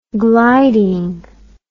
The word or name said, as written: gliding